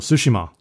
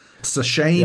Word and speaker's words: Tsushima: Well, Tsushima. We can pronounce it properly now. – Yay. Yeah, you know how to say it. – Tsushima.